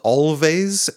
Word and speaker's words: Alvvays: Oh yeah, and always, and if anyone's just listening to me and doesn't know what I'm talking about, it's spelled always, A-L-V-V-A-Y-S, but it's pronounced always, and the two V's make a W.